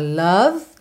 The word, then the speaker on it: love: There's a love that cannot lie. Love is strong. Love is strong. Percebe como é tudo ligadinho. O é do love não é pronunciado, eu já ligo no I.